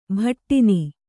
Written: ಬಡಿನ

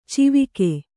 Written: ಚಿವಿಕೆ

